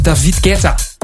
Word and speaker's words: David Guetta: Maar ja, het is een Fransman, dus dan zeg je het gewoon als David Guetta. – Ja, ik denk dat de meeste Fransen het ook wel zo uitspreken.